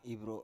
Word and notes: ivro: Biak

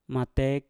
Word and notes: matek: Mpur

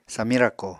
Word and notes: samiñ rako: apatani

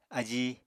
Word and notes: ají: apatani

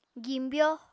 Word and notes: gimbyo: Apatani

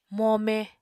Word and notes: mome: Apatani

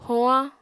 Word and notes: hoañ: apatani

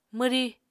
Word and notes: mwrí: Apatani

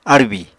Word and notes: árbì: Apatani

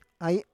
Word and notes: ai: Roon

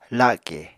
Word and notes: la7ke: Apatani

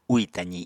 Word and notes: úì tazi: Apatani

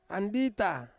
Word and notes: andita: Ansus

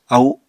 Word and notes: au: apatani

